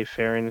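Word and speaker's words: Ferren: I believe that's how you pronounce her name F E R R E N. I would say Farron is how you would probably pronounce it.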